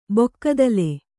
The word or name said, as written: ಬಕ್ಕತಲೆ